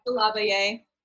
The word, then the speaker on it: DeLarverie: Delavaye. It's spelled Delarverie, but it's pronounced Delavaye.